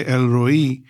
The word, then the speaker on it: El Roi: The name, the title I want to dwell on, meditate on today, is Elroy, E-L-L-Roy, R-O-I. Or you could pronounce it as Roy, Elroy. And depending on how you pronounce it, even the meaning changes. The meaning is God sees, or if you say El Roi, it means God sees me.